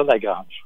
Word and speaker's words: La Grange: Hey, comment toi, tu prononces ça comment? La Grange en anglais, mettons? – On va dire La Grange. – OK, c'est parfait.